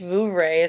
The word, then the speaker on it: Vouvray: French Vouvray is correct.